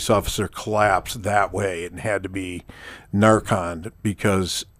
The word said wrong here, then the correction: Narcan: Narcan? – Yeah, Narcan.